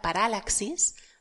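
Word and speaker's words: paralaxis (παράλλαξις): Dice que viene de la palabra paralaxis, lo pronuncia así esdrújula porque tiene una tilde, un acento en la, la, segunda A, la segunda alfa.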